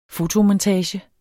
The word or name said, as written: fotomontage